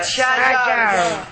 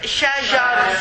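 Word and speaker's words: Shajar (شجر): Shajar. Excellent. Shajar. Again, all of us, sister. Shajar. Shajar. It is not an easy word because the sheen and the jim are close together when you pronounce them.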